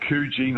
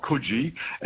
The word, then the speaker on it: Coogee: And over there's a place called Coogee, not Coogee.